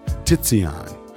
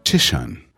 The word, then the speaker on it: Titian: Tizian, or the proper English pronunciation is Titian.